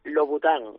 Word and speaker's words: Louboutin: También, como decía, vais a premiar a Christian Laboutian o Laboutian. ¿Cómo se pronuncia, hijo? – En francés, por lo visto, yo que no soy un gran hablador de francés, pero la OU se pronuncia como una U. – Sí, Laboutian. – Louboutin. – Ah, Louboutin, Louboutin, eso.